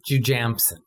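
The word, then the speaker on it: Jujamcyn: Jujamson.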